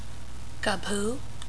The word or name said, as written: Kabhoo